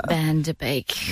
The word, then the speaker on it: Van Der Beek: James Van Der Beek.